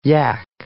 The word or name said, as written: yack